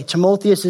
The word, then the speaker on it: Timotheus: Timotheus is how you pronounce it, but he's called a true uh, son of the faith.